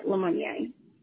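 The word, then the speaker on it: LeMeunier: Did I say that? Pronounce it right? I want to sound like Lemonnier. Am I right? – Lemonnier.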